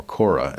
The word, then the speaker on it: Korah: Kor, or Korah, as it would be pronounced in the Old Testament.